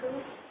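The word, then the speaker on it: Kaf (כף): And then it has two kafs. Did I say that right? K-A-F, I believe. No? – Or kuf? – Kuf? – It's a Q. – Kaf. – C-A-F, kaf. – Yeah, or K-A-F, depending on how you look at it.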